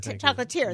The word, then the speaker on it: chocolatier: And Edward Mark Chocolatier, located in Pittsburgh. Is that how you say it? – Chocolatier. – Yeah. There we go.